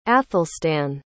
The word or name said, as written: athelstan